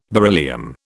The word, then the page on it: beryllium: British English